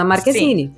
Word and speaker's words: Marquezine: Não, o nome dela, se tu vai falar em português com a pronúncia em português, é Bruna Marquezine.